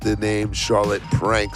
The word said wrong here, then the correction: Charlotte Plank: so forgive me for my earlier mistake.